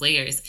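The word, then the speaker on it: L@yerz: My name is Layers. It's spelled L with the @ Y-E-R-Z. L with the @ Y-E-R-Z.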